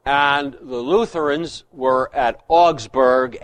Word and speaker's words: Augsburg: Well, in English, we say Augsburg. It's Augsburg. A-U-G-S-B-U-R-G. Augsburg. What are these Anglo-Saxons going to stop mispronouncing good German words?